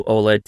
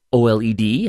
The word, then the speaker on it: OLED: OLED TV, or OLED, however you wish to pronounce it. Both are correct.